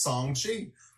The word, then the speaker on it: Shang-Chi: And by the way, we learned this week that Shang-Chi is pronounced Song-Chi.